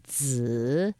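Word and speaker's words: zi (子): And so then the lotus seeds is a zi, like sun.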